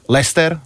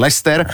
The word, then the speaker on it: Leicester: Crystal Palace, Leicester, nikdy neviem, ak sa ten Leicester vyslovuje. – Leicester. Odkedy vyhrali, odtedy viem, ako sa to vyslovuje. – Aha, takže Leicester.